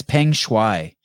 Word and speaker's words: Peng Shuai: Her name is Peng Shuai.